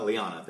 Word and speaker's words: Lyanna: How do you pronounce that? Liana? Liana, thank you.